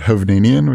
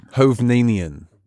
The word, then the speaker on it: Hovnanian: I pronounce it Hovnanian, which I think is about right. – Hovnanian.